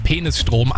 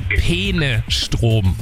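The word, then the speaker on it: Peenestrom: Und es ist, also ich bin mir sicher, sie spricht es absichtlich so aus, weil sie sagt ja eindeutig Penisstrom, aber es geht um den Fluss Peene und es ist der Penestrom.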